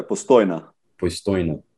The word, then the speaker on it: Postojna: And what about this cave, Postojna? How do I pronounce it? – Yeah, Postojna, yeah.